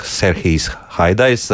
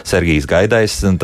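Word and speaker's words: Serhijs Haidajs: Jā, bet kad uh, sāk uh, eh, Serhijas Haidais, uh, kur raksta Sergijas Gaidais, tad tas ir nepareizi. Gā ir jāizrunā kā gā, nu Jānis Taums raksta.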